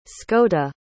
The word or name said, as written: scota